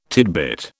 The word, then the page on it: tidbit: British English